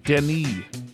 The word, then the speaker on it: Denis: Like Dennis did, but it's pronounced Denis. Well, that's what that Denis short for Dennis. – It's Denis. It's French. – What? – So the S is silent? – How did he leave a message?